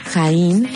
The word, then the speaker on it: Jain: Así que aquí te dejo con Jaín, se escribe J-A-I-N, Jaín, y su canción Makeba.